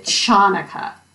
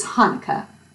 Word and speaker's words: Hanukkah: So actually, the CH spelling or the two H spellings are all widely accepted. Just don't pronounce it Chanukah. So what exactly is Hanukkah?